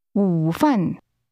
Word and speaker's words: 午饭: 吃午饭, 饭, 午, 午饭, 午饭, Lunch is pronounced with a falling, rising tone, followed by a falling tone. How do you say lunch? – 午饭.